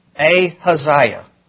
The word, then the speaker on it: Ahaziah: It's a long A, ha, long Isaiah. Ahaziah. Ahaziah. That's how you pronounce it. Ahaziah. Kind of like Uzziah, but Ahaziah.